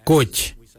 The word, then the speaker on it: goodj: Garli is spelled G-I-I-L-Y-I, garli. For the traditional stone axe, we say gotch, gotch. Gotch is spelled G-O-O-D-J, gotch.